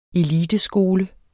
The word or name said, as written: eliteskole